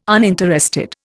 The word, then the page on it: uninterested: Indian